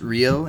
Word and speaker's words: Ryu: 2035 is just going to be Ryu. Ryu. Uh, it's pronounced Ryo, and you're talking about Shenmue.